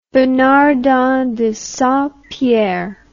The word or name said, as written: Bernardin de Saint-Pierre